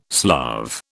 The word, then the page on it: Slav: British English